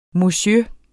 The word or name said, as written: monsieur